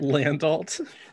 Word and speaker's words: Landolt: Landolt.